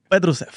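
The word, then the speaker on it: Petrušev: Flip Petrusev.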